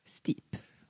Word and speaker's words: Steep: Och då tipsade de om ett spel som heter antingen Step eller Steep beroende på hur två E efter varandra uttalar sitt ord. – Steep.